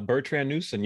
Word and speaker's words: Bertrand Newson: You are Bertrand Newson.